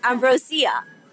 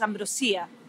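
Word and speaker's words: Ambrosía: I wanted you to say the name again before I did, because I've been saying Ambrosia, and it's Ambrosia. – Yes, well, I'm sorry. – In Spanish, it's Ambrosia, but we put an accent on the I, so it's Ambrosia. That's how we pronounce it.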